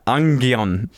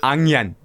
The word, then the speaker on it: onion: People who pronounce onion like ungion. Ungion. Onion. You know how to say that? Onion.